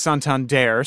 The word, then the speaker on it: Santander: Well, his last name is pronounced Santander, so the Daredevil.